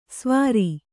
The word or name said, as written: ಸವಾರಿ